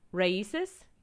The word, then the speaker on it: Raices: And then the last but not least, Raices. – Mm-hmm. – Okay. Not my white ignorant ass saying it was like races without the I.